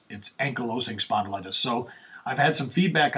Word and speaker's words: ankylosing spondylitis: Well, not really an article, but 29 things only someone with ankylosing spondylitis would understand.